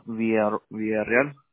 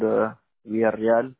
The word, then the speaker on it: Villarreal: Medina Villarreal.